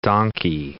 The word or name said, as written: donkey